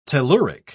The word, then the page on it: telluric: British English